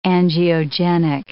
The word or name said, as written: angiogenic